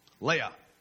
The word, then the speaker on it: Leah: The older daughter was Leah. And yes, that's the way it's pronounced. I'm not just going Star Wars.